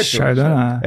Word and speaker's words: Chardonnay: Ele é 70% uvas Chardonnay, falei certo? – Chardonnay. – É, e 30% Pinot Noir? – Pinot Noir.